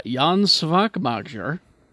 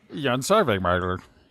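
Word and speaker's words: Jan Švankmajer: It's a foreign film by Jan Svankmajer. – You said and that wrong. – I know that was perfect. – Okay, you say it rightly. – Jan Svankmajer.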